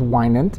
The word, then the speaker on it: Winant: And if you don't mind, just tell us a couple of those stories briefly about, now, tell me about John Winnett. Is that the way you pronounce his last name? – I think it's Winant. – Winant.